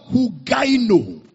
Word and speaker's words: hugaino: Sound doctrine is the word hugaino in the Greek. Hugaino, from the word hugis. Hugaino is spelled as H-U-G-A-I-N-O, from the word hugis.